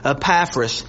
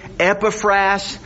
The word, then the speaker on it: Epaphras: Epaphras. Now some would say Epaphras. Either way is fine. Epaphras or Epaphras. Either pronunciation is fine.